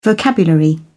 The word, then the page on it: vocabulary: British English